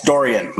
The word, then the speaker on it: Dorian: It's Dorian, like Dorian Gray.